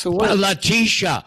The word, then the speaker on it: Letitia: AG Letitia James. – Letitia. Letitia. Pronounce it correctly. It's Miss Letitia.